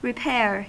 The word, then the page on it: repair: [ɹɪ.ˈpɛɹ]; American English